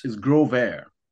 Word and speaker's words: Growvare: It's Growvare.